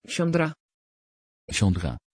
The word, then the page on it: chandra: Dutch